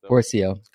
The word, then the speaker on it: Pourciau: Did I say it right? I feel like I butchered it. – Pourciau. – Close.